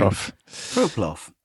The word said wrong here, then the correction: plongeur: Plongeur professional. It's a contraction of two words. I think French. It's a French word, plongeur.